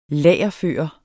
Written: lagerføre

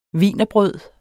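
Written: WIENERBRØDENE

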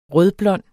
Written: rødblond

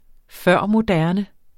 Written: førmoderne

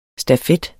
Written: stafet

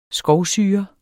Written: skovsyre

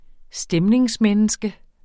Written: stemningsmenneske

